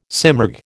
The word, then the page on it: simurg: American English